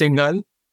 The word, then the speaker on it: Singhal: It's Singhal.